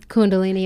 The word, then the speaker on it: Kundalini: Kundalini yoga. – Kundalini yoga with that gong happening.